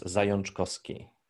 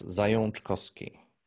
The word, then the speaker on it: Zajączkowski: Polish pronunciation is Zajączkowski. – You'd put an N in it? It sounded like you put an N in it. – Yes, it's Zajączkowski. – Huh. I just see the Z-A-J-A-C-Z, and I would have gone Zajączkowski.